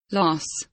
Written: loss

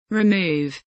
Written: remove

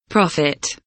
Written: profit

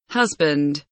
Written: husband

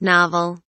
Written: novel

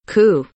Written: coup

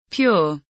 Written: pure

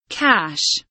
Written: cash

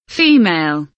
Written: female

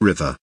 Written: river

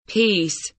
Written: peace

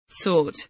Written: sort

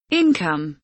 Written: income

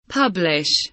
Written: publish

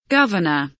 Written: governor

